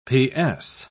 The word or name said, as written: ps